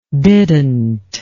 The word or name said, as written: didnt